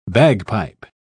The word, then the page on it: bagpipe: British English